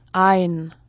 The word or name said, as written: ein